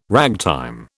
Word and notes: ragtime: British English

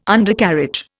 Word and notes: Undercarriage: Indian

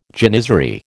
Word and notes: janizary: American English

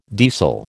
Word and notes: deasil: American English

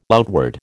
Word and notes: outward: American English

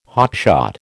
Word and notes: hotshot: American English

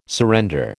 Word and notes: surrender: American English

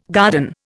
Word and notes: garden: Indian

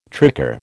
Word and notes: tricar: American English

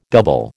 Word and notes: double: American English

